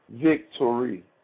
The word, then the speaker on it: Viktory: Gospel song by a gospel rap artist named Vic Torrey. It's spelled V-I-K-T-O-R-Y. Vic Torrey.